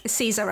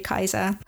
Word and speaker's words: Caesar: Like Caesar, I'm pronouncing wrong as well. It should be Kaiser because the Latin does not have soft C's. We have softened them, so it should be Cicero and Kaiser.